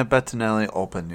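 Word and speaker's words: Bettinelli-Olpin: I'm going to butcher the last name, but Matt Bettinelli? – Let me see. – Bettinelli-Ulpin? – Yeah, Bettinelli-Ulpin, yeah.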